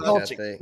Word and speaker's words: Dulcich: Is that how you Dulcich, pronounce it? Dolchik.